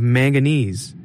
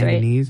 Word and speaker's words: manganese: And 11,000 tons of manganese. Am I saying that wrong? – Yeah, I think that's manganese.